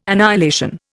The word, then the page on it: annihilation: Indian